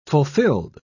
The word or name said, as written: fulfilled